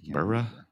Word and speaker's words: Middlesbrough: Middlesbrough, right? Is that how you say it? – Middlesbrough.